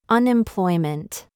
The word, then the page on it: CHOICE: American English